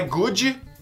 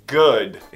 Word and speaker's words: good: Não é good, é good. Esse D é bem suave. Good.